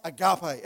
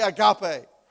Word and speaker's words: agape: So it looks the same, but it's pronounced different. And probably the way I've pronounced it is equally as wrong. So let's not get caught up about that. But as far as what I can understand, it's pronounced agape. Everyone say agape.